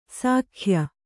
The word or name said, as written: ಶಾಕ್ಯ